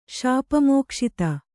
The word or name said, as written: ಶಾಪಮೋಕ್ಷಿತ